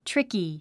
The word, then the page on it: tricky: tríki; トゥリキィ